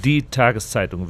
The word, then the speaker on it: Die Tageszeitung: Die Tageszeitung, which simply means the daily paper.